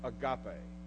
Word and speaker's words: agape: It has an A sound. And it's pronounced agape.